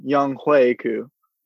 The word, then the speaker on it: Younghoe Koo: I just want to correct your pronunciation. Younghoe Koo.